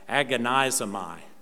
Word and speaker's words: agonizomai: That word strive in the Greek is the word you can hear the English in this, agonizomai. That's the David Ray Greek pronunciation. Agonizomai. And you can hear the word agony.